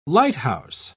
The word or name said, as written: lighthouse